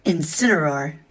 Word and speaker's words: Incineroar: That is a Gardevoir, a Venusaur, Incineroar, a Dreadnought, and Eldegoss.